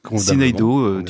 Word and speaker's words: Syneido: Et puis depuis peu, Sineido, je ne sais pas si je le prononce. – Sineido, tu, le vous prononcez très très bien.